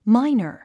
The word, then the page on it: miner: máinər; マイナァ